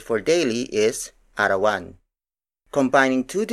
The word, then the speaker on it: arawan: For example, arawan, arawan, arawan.